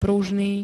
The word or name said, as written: pružný